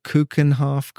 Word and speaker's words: Keukenhof: And then there's the Keukenhof Gardens. I don't know if I said that right. – Yeah. – Sounds right. – Yeah. – Good enough.